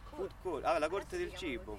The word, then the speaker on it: food court: quindi una food court, la pronuncia è giusta,